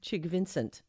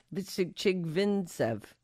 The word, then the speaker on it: Chigvintsev: Artem Chigvincent. Chigvintsev.